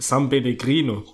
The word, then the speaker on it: San Pellegrino: Ah, das ist ja Sunpilly Alter, ich kann das nicht aussprechen. San Pellegrino.